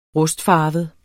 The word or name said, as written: rustfarvet